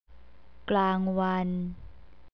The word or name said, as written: klangwan